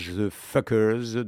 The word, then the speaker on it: FCKUKER: Ça s'écrit F-C-K-U-K-E-R, ça se prononce The Fuckers.